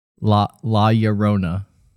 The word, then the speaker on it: La Llorona: How pronounce it again. – La. – La. – Ya. – Ya. – Ro. – Ro. – Na. – La Llorona. – You said it perfectly.